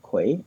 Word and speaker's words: Kwei: My name is spelled K-W-E-I, Quay, and Corte is Q-U-A-R-T-E-Y.